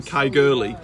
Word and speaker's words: Caergwrle: I'm not going to go through it all, but I tried to sort of make the argument that us people here saying Kai Gurley are not too far from how it should be pronounced anyway.